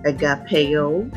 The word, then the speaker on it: agape: Now you can pronounce agape as agapeo, agapeo, and you can pronounce philia as phileo. Okay, either or. So, Peter used the word philia or phileo each time he answered Jesus.